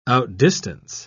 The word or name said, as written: outdistance